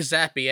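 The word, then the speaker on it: Zappe: It's Bailey Zappi.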